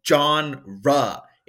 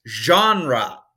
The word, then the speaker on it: genre: Like you're saying, like you're quickly saying the name of a guy called John Ra. It's genre Seriously now.